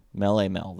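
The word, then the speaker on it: Melle Mel: It's possible I mispronounced it because it's M-E-L-L-E-M-E-L. So Mele Mel? – That would be better. – Okay. Mele Mel then.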